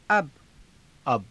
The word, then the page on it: अब: aba